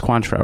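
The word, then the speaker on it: Cointreau: I've never been able to pronounce Quantra. It's Quantra, right? – Quantra.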